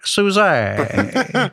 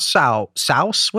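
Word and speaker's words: Sousé: Field's name, Egbert Suzay. – it's a grave over the E. Accent. – Yeah, what is it? A downward accent on the E. – Yeah, not souse.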